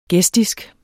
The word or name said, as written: gestisk